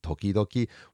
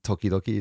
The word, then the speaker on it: tokidoki: Sorry, just to go back to tamani and tokidoki. What's the intonation, the pronunciation for tamani? – Tamani. – Tamani. Mm-hmm. Okay. And then tokidoki is what? – Tokidoki. – Tokidoki. – So pretty similar.